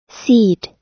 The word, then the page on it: seed: American English